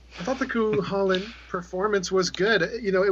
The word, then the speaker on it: Cúchulainn: What is it? – Cúchulainn. Cúchulainn. – Cúchulainn. Yes.